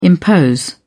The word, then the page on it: impose: British English